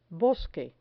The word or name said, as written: bosque